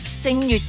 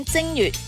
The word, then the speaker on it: 正月: 譬如秦始皇名嬴政，臣民为了要避讳正月的正，就要读成正了，直到现在一样。所以一月份我们会讲正月，不是讲正月的。